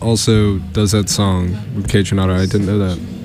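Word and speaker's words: Kaytranada: Okay, first of all, it's pronounced Kai Tranada.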